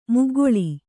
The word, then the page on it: ಮೊಗ್ಗಳ: kannada